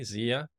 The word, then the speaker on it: Zia: Am I pronouncing it correctly, Zia? – Yes, you're pronouncing it right.